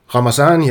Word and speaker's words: Ramazani: og så ham her Ramazani, ja, det er sådan, det skulle udtales.